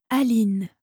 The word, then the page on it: Aline: French